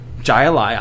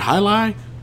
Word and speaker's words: Jai Alai: J-A-I-A-L-A-I. – Isn't that High Lai? – High Lai IPA. Jai Alai? – I think that's High Lai.